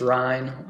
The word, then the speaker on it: Ryne: Yeah, Instagram's my most used form of social media, and it's Ryne, R-Y-N-E, 1 Anderson on there. – You do a better job of pronouncing the Ryne, the Y in your name, than I think everybody else does. You're probably used to it by now. – Yeah, it's the southern part.